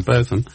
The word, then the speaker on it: Botham: Apropos pronunciation of cricketer's name, Botham. His name should be pronounced Botin. Should it? Now, it's Botham or Botham. He doesn't mind which.